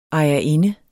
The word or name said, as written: ejerinde